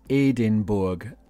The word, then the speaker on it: Edinburgh: Pronunciation Edinburgh sounds like my name, but it's Aidin, Edinburgh, without an H on the end.